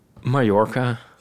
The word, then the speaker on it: Mallorca: Het is met twee L'en. Het is Mallorca met ja, dan is het, spreek je het uit als een J.